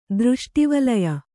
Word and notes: ದೃಷ್ಟಿವಲಯ: kannada

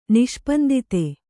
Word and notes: ನಿಷ್ಪಂದಿತೆ: Kannada

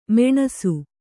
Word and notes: ಮೆಣಸು: meṇasu

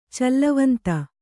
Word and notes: ಚಲ್ಲವಂತ: kannada

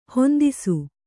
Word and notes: ಹಿಂದೆಸೆ: hindese

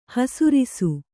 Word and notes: ಹೆಸರಿಸು: hesarisu

